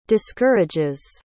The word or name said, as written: discourages